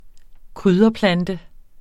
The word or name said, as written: krydderplante